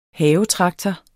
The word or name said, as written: havetraktor